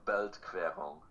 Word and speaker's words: Beltquerung: Belkerung? How do you say it? – So, Beltquerung. – Okay. Beltquerung.